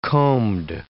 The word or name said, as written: combed